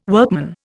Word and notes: workman: Indian

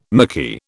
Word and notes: mickey: British English

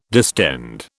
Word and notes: distend: British English

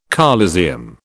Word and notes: coliseum: British English

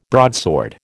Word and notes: broadsword: American English

